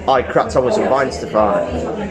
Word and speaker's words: Weihenstephaner: Brewed by the Bayerische Staatsbrauerei Weihenstephaner. the Bavarian State Brewery, Weinsterfahrer, competently pronounced.